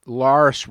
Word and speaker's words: Lars: He says that Lars rhymes with farce, not with cars.